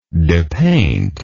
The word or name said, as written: depaint